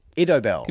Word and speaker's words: Idobel: The funny thing is, he never called me Idobel. – Pardon? – Idobel. – Can you spell that for me, please? – Idobel. So like Isabel, but with a D and just Isabel the most...